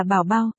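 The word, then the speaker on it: bảo bao (宝宝): Bé cưng tiếng Trung là bao bao, đọc là bảo bao, rất dễ sức nghe.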